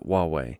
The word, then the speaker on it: Huawei: Well, it's interesting because I talk to people about what this company is and who this HUA and WEI, well, you pronounce it Huawei.